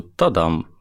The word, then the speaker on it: Тадам: Да нет, на самом деле ударение обязательно ставить, просто «Тадам» обычно, спокойно.